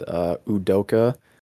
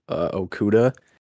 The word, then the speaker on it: Udoka: I think it's kind of a toss-up between Rick Carlisle and Udoka, who I previously pronounced Okuda, which is not the NFL cornerback, because they both had kind of disappointing seasons, the Celtics and Pacers.